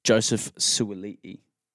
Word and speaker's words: Suaalii: Lastly, Joseph Suaalii. Did I say that right? – Yep, you said that right.